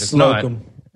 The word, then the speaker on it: Slocum: That, well, you bring up Douglas Slocum, which I'm assuming is how you say his name. – I think it's Slocum. – Slocum, who only shot these three Indiana Jones films for Spielberg.